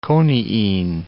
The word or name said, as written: coniine